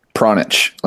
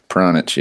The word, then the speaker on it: Pronich: And give me the correct pronunciation of the last name. – Pronich, like a little piranha. Shrimp and a scratch. – Yeah, like a piranha itch.